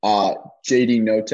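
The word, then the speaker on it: JD Notae: And then worse, which is probably most important from a coaching perspective, JD Note, is that how you pronounce it? – Yes.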